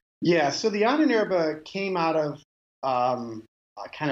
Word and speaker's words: Ahnenerbe: He has a segment of the SS, and you can correct me on the pronunciation, the Ahnenabe? Is that how you say it? – The Ahnenerbe.